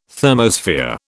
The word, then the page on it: thermosphere: British English